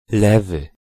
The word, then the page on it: lewy: [ˈlɛvɨ]